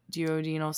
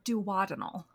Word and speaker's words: duodenal: And they switched you from the SADI to the duodenal switch. I'm probably not saying that properly. – I was told you could say it either way, the way you just said it, or duodenal.